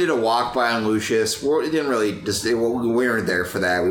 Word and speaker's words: Lucius: How do you say Lucius? – I think it's just Lucius.